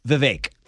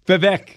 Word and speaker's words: Vivek: Vivek. – Vivek. – Yeah. – Because it's a V-E-K. – But it rhymes with cake in the way you pronounce it.